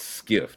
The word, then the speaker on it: SCIF: S-C-I-F is pronounced skiff.